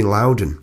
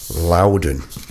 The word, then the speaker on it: Loudon: call me Loudon." Ooh, so there you go, from the horse's mouth. Loudon Wainwright III pronounced his name Loudon.